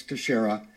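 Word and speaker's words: Teixeira: How's it pronounced? Texera? – Jack Douglas Texera.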